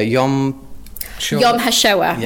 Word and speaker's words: Yom HaShoah: In Manchester, Yom HaShoah, yeah, in Manchester.